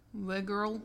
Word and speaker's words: Wegerle: Vicky, I'm just going to butcher her poor name. Wegerle? W-E-G-E-R-L-E? Wegerle? – Yeah. – This one? – Wegerle.